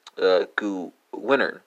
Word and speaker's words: gewinnen: Spelled W-I-N-N-E-N for the Dutch, and it's going to be gewinner, spelled G-E-W-I-N-N-E-R. I'm sorry, G-E-W-I-N-N-E-N in German.